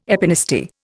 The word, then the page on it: epinasty: Indian